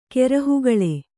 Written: ಕೆರಹುಗಾಲ್

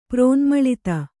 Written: ಪ್ರೋನ್ಮಳಿತ

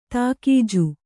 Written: ತಾಕೀಜು

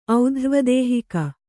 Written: ಔಧ್ರ್ವದೇಹಿಕ

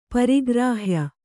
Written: ಪರಿಗ್ರಾಹ್ಯ

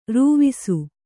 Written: ರವಸ

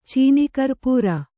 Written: ಚೀನಿಕರ್ಪೂರ

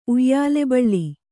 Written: ಉಯ್ಯಾಲೆಬಳ್ಳಿ

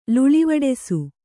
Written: ಲುಳಿವಡಿಸು

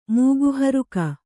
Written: ಮೂಗುಹರುಕ

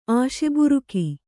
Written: ಆಶೆಬುರುಕಿ

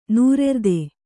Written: ನೈರ್ಋತ

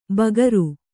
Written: ಬಗರು

